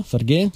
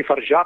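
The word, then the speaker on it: Fargeat: Si tratta di The Substance della regista Coralie Farget, credo si dica Farget, si dice così, non so, credo si pronunci Farja perché è francese.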